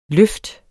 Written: løft

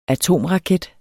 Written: atomraket

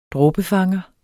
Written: dråbefanger

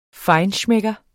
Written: FEINSCHMECKERE

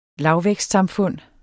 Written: lavvækstsamfund

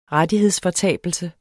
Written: rettighedsfortabelse

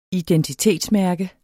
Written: identitetsmærke

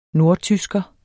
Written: nordtysker

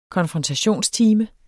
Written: konfrontationstime

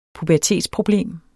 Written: pubertetsproblem

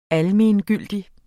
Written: ALMENGYLDIG

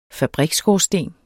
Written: fabriksskorsten

